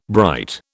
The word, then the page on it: bright: British English